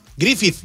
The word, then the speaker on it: Griffith: Cada vez que ponga la TH como the, como THE. – A ver, Griffith. ¿Cómo se dice, pues? – Griffith.